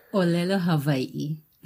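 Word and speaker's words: Olelo Hawaii (ʻŌlelo Hawaiʻi): Ja, auf jeden Fall bei Brian Tolentino, Jeremy Carmen Hopkins studierst du hawaiianische Musik, Spieltechnik und jetzt halt helf mir mal mit der Aussprache, Olelo Hawaii? – Olelo Hawaii. – Okay, kriege ich so nicht hin.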